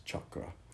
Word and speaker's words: chakra: And it's not a big thing, but the correct pronunciation is chakra, if you want to stay true to the Sanskrit tradition.